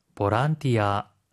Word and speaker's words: ボランティア: ボランティア、ボランティア。